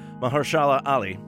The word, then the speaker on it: Mahershala Ali: I have to get the pronunciation right because I've been also mangling this as well too, Mahershala Ali, best supporting actor.